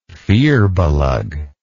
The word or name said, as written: firbolg